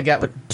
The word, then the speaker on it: Gatwick: Am I saying that right? Gatwick? I don't know. – Yeah, Gatwick.